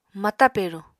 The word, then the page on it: mata peruñ: Apatani